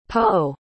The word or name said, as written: poh